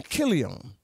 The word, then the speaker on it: Chilion: The name of the man was Elimelech, and his wife, Naomi, and the name of his two sons were Mahlon and Chilion. That's how that's pronounced.